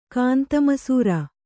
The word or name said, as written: ಕಾಂತಮಸೂರ